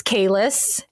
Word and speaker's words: Kalis: So Kalis is a reference to Star Trek The Next Generation, because the leader of the Klingons was known as Kalis, and it is pronounced the same way. It is just spelled differently in this case.